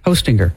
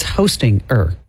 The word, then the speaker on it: Hostinger: H-O, like Santa, ho, and then stinger. – Hostinger. – Like a bee has a stinger. – But it's Hostinger. – Yeah, that's just how you spell it. – But it's like hosting, and then you put an ER at the end.